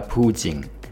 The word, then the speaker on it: 普京: 嗯、那我之所以习惯说普京呢，是因为用俄文发音，它叫普京，我觉得这个发音才是贴切他的真实。